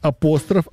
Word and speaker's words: апостроф: Как правильно говорить? Апостроф, апостроф или допустимы оба варианта? – Апостроф. – Допустимо оба варианта.